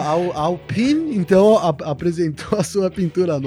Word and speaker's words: Alpine: Sério que a pronúncia em francês é Alpine? Eu fui atrás, é Alpine mesmo, né? – Então é, vou começar a falar é.